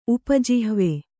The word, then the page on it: ಉಪಜಿಹ್ವೆ: upa jihve